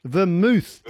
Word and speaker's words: vermouth: So it's a type of drink that ends with the letters of mouth, but it's not pronounced mouth. It's pronounced mooth. What is it that Cinzano bottles? – Vermouth is what I'm looking for.